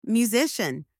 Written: musician

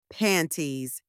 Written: panties

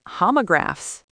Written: homographs

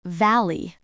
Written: valley

